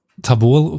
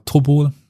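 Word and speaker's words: Tabol (Тобол): Aber der Fluss Tabol können wir jetzt, oder Tobol, also können wir so ein bisschen Russisch einbringen. Es gibt ja diese Reduktionsstufen auf, auf Russisch, also das erste geschriebene O ist eigentlich, wird ausgesprochen mehr wie ein A.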